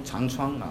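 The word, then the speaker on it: 常枞: 中国，中国道家的创始人呢，他叫我们知道叫老子啊，他有个师傅啊，他有个师傅叫长啊老师，他的老师叫长窗啊，这个字到底怎么念呢？可能每个有不同的念法，但是我就念他窗长窗啊。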